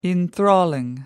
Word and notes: enthrall: American English

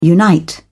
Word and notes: unite: British English

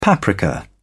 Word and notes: paprika: British English

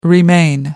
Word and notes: remain: American English

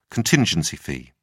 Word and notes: contingency fee: British English

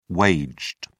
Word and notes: waged: British English

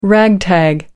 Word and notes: ragtag: British English

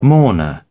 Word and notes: mourner: British English

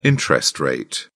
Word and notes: interest rate: British English

